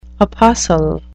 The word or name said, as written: apostle